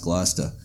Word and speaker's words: Gloucester: which is the Annisquam Village is part of Gloucester, Massachusetts, or Gloucester, properly pronounced. Yeah. I'm from not far from there, so I know it's pronounced Gloucester.